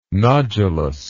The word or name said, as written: nodulus